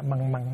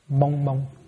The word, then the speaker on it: mông mông: Trong này để là xả măng măng, nhưng cái thực ra đọc là mông mông.